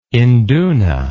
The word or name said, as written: induna